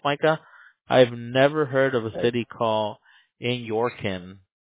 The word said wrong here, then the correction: Inyokern: I don't know if I'm even saying that correctly. – You're not. Nobody does. It's okay. It's called Inyokern.